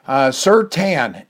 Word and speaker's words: Surtain: Surtain is how you pronounce it, T-A-N, as in get a tan from outside.